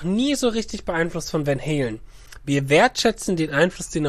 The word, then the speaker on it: Van Halen: Nein, du hast es schon richtig gesagt. Van Halen,